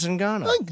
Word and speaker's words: Ngannou: Francis Ngannou.